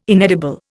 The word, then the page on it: inedible: Indian